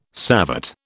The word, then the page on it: savate: British English